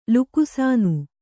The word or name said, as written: ಲುಕ್ಸಾನ